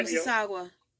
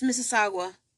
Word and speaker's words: Mississauga: On May 30, 1988, rape of an 18-year-old woman in, I don't know how to say this name, Mississauga? – Yeah, Mississauga. Yeah, it's Mississauga.